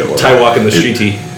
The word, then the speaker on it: Waititi: Taika Waititi. – He's married to Rita. – Taika Waititi. I don't know how to say his name. – So he said it right the first two times.